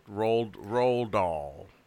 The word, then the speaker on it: Roald Dahl: It's Ronald Dahl. Ronald Dahl. Roald Dahl. I can never say his name. – Also, I know, it's hard. It's like Roald Dahl.